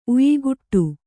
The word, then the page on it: ಉಯಿಕುಟ್ಟು: uyikaṭṭu